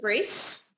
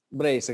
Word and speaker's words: Brace: Nico is a chef based in Copenhagen and he has a restaurant that I would like to call Brace, but I just discovered that you pronounce it Brace. – Brace, exactly, yeah. It's the English version, yeah.